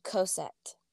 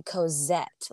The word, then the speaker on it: Cosette: Since it's French, there's a couple different options. I pronounce my name Cosette. – Okay. – Yes, but if you were in France, you'd probably say Cosette, like with a Z sound.